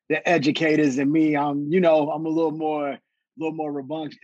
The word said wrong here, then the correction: rambunctious: Is that how you say the word? Am I saying it right? Rebunctious? How you say it? You know the word I'm trying to say? Rambunctious. – There you go.